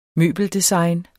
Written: møbeldesign

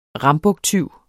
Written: rambuktyv

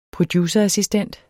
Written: producerassistent

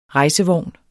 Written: rejsevogn

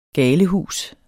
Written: galehus

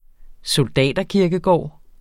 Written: soldaterkirkegård

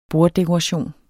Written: BORDDEKORATION

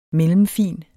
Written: mellemfin

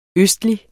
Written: østlig

